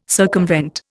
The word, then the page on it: circumvent: Indian